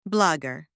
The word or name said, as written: blogger